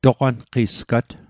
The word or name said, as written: toqwanqisgat